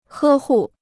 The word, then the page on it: 呵护: hē hù